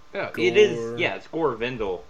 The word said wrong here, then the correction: Vidal: you're not pronouncing it correctly. You're adding right. – an extra letter. An extra consonant. Yeah, there's no N in it. – Vindel? – It's V-I-D-A-L.